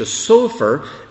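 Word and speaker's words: sofer (סופר): But if you change the pronunciation, not to change the spelling, just change the pronunciation to sofer, it means a scribe, one who makes Torah scrolls and who writes books, a sofer.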